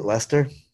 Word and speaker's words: Leicester: I can never keep it straight, but at least I know how to pronounce it, right? Lester. I grew up near a town called Worcester, spelled like you don't even want to know.